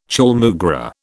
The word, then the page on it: chaulmoogra: British English